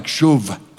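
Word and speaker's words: shuv (שוב): Restoration involves, even in the Hebrew, it involves, it's a composite word there, where it talks about shuv. If I pronounce it like shuv, I'd come closer to it.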